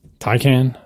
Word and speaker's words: Taycan: a good example is Porsche with the Taycan. Is that how you pronounce it? I forget. – I think that's right.